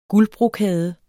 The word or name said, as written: guldbrokade